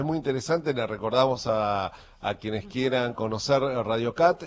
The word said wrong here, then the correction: Radio Cut: es Radio Cut,